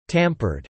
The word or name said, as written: tampered